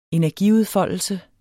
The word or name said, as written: energiudfoldelse